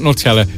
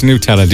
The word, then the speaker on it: Nutella: And that's how you pronounce it, by the way. It's not Nutella, it's Nutella.